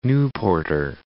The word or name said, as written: Newporter